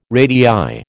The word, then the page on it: radii: American English